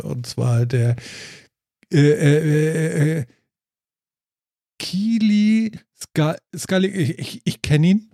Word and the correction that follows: Kiel is Calling: Ähm, Du meinst Kiel is Calling?